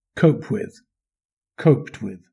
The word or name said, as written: cope with